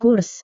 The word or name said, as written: kurs